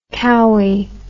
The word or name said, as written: cowy